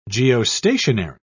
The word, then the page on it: geostationary: British English